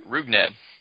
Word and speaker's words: Rougned: How do you say his first name? – Rougned. – It's Rougned, right? – Adore.